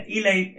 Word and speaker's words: Eli: Y hoy tocó Eli, Eli, dependiendo cómo lo quiera. Eli es el nombre correcto, es la correcta pronunciación.